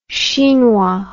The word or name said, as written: chinois